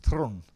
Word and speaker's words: Theron: That was Charlize Theron talking of Theron, actually, to be perfectly honest. That is how you pronounce her name.